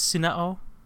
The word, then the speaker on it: Sunao: Uh, I think it's Sunao because usually vowels are separate uh, si- uh, syllables.